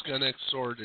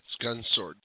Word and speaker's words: Gun X Sword: Yes, uh, one thing though, it's not pronounced gun X sword. It's gun sword. The X is always silent.